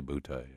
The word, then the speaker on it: Boutte: I want to say Boutte. – Right. We'll have some respect and class. – But I think the pronunciation is indeed Booty.